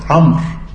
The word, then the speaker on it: عمرو: اه طبعا تنطق عمرو عمرو وتزاد الواو.